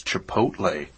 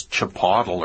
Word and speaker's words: Chipotle: Well, I'll tell you, I'm glad I'm here today because I learned how to pronounce Chipotle. I thought that was Chipotle or Chipotle.